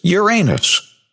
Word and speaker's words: Uranus: For the longest time, ever since I was a kid, the pronunciation and the accepted pronunciation astronomically of this planet is Uranus.